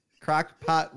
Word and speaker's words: crock pot: I had a cock block roast. Yeah, there's just so many different around it. Crock pot roast. I said it right, right? Everyone's rubbing their eyes and seeing like I didn't say it right.